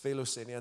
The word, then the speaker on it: philoxenia: Hospitality itself, it comes from this Greek word, philoxenia, right? Is how you pronounce this, philoxenia.